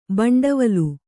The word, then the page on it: ಭಾಂಡವಲು: bhāṇḍavalu